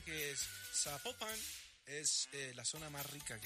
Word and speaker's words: Zapopan: Oiga, nos escriben los oyentes y que no se pronuncia Zapopan, sino que se pronuncia Zapopan. – Ah, Zapopan. – Ah, bueno, es Zapopan.